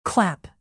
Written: clap

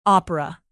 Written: opera

